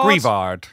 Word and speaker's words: Greavard: Greavard. – Greavard. – Greavard.